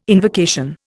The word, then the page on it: invocation: Indian